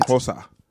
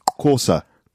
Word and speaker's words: Xhosa: It's spelled X-H-O-S-A. – And Corsa. – X-H is Co.